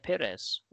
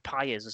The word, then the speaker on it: Pires: I went for Robert Pires, or Robert Pires, as he'd be pronounced on our local radio, but that's another complaint for another time.